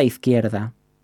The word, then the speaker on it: izquierda: Now, let's try it's on the left, which in Spanish is está a la izquierda. Está a la izquierda. Let's break it down by syllable. Es ta a la izquierda. And now let's hear it once again. Está a la izquierda.